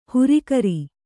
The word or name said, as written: ಹುರಿಕರಿ